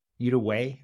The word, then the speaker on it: Uruguay: Uruguay, I think it's the, the way you pronounce it in English.